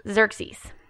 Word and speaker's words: Xerxes: Xerxes.